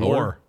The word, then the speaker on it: Oher: Oher.